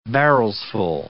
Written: barrelsful

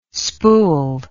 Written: spool

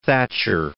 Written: Thatcher

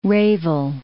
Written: Revel

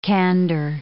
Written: candor

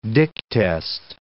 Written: Dick test

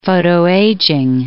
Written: photoaging

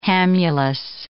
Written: hamulus

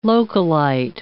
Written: localite

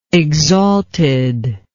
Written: exaltedness

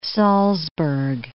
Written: Salzburg

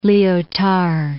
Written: Liotard

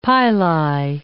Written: pili

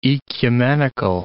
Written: oecumenical

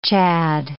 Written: Chad

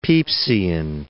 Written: Pepysian

